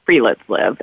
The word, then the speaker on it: friluftsliv: It's called Free Let's Live, and it's spelled FRILUFTSLIV.